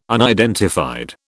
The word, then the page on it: unidentified: British English